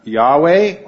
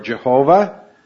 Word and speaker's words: Yahweh: We pronounce that Yahweh or Jehovah, the covenant name of God.